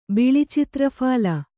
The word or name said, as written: ಬಿಳಿಚಿತ್ರಫಾಲ